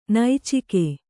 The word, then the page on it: ನೈಚಿಕೆ: naicike